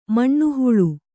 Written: ಮನೆಹಾಳು

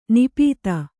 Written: ನಾಪಿತ

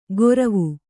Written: ಗೊರವು

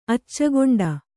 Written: ಅಚ್ಚಗೊಂಡ

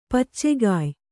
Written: ಪಚ್ಚೆಕಾಯಿ

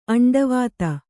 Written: ಅಂಡವಾತ

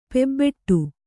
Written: ಪೂಬಿಡು

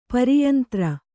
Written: ಪರಿಯಂತರ

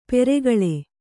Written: ಪೆರ್ಕಳ

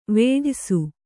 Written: ವೇಢಯ್ಸು

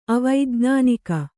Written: ಅವೈಜ್ಞಾನಿಕ

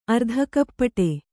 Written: ಅರ್ಧಕಪ್ಪಟೆ

